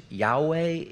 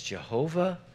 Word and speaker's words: Yahweh: It's pronounced by some people as Yahweh. It's pronounced by some people as Jehovah.